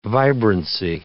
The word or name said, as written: vibrancy